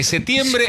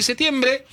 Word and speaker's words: septiembre: 9 de septiembre, sí, amo decir septiembre sin P, sepan mis compañeros, porque ya vi que varios revolearon los sí, ojos mira, como diciendo la P, sí, donde la pronunciación, locutón. Sepan que me gusta decir septiembre, sí, no digo septiembre, digo, es una letra más que tengo que pronunciar.